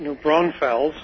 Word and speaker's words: New Braunfels: New Brownsville, Texas. Is that right? – Good pronunciation, Dr. Stan. That's New Braunfels.